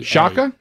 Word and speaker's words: Shaka: His first name, S-H-A-K-A. – Shaka? – Shaka Hislop. I don't know how else you would say Anybody ever heard of Shaka Hislop? – No.